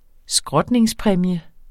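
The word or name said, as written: skrotningspræmie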